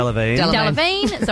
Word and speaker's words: Delevingne: Delevingne.